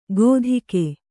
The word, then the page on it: ಗೋಧಿಕೆ: Kannada